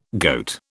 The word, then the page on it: goat: British English